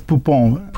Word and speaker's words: Poupon: My last name is spelled, it says Poupon.